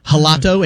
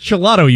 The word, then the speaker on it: gelato: And I thought it was like a soft G, like you might pronounce a J in Spanish. So I was like, I'll have the gelato. And he's like, ah, the gelato. Yes, yes. – It's just gelato, you idiot.